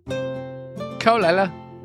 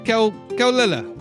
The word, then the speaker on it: Caol Ila: You're going to pronounce this for me. – What? Kaulala. – Yes. – Hey. – Kaulala. – Kaulala Distillery. – C-A-O-L-I-L-A. Two separate words.